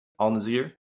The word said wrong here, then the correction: Al Nassr: I believe it's Al Nassr.